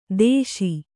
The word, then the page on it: ದೇಶಿ: Kannada